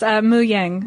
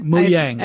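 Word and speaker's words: Mojang: Is it Muyang is the name of his company? We'll get into it, but, yes, Muyang. – Muyang. – I think is how you say it.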